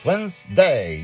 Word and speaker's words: Wednesday: Oh, I said Wednesday, two syllables, Wednesday.